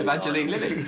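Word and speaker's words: Evangeline Lilly: Pronounced Evan Jelly. – Evangelion. – Evangeline Lily.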